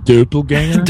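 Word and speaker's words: doppelgänger: And it's actually pronounced doppelganger.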